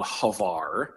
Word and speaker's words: Hvar: And the island is called Hvar. It's spelled H-V-A-R.